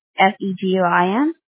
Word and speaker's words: Seguin: Oh, Seguin, is that Seguin? – Yes, ma'am.